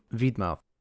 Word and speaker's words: Vytmadh: V-Y-T-M-A-D-H, which if I didn't know how it sounded from hearing that word in game, I would probably pronounce it Vitmad, which isn't far off. It's actually Vidmouth. It sounds like white mouth, and it means white maw, essentially.